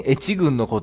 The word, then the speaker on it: 愛知郡: 滋賀県越知郡のことを愛知郡と読むと思い始めている。愛知って書いて越知郡って読むんや。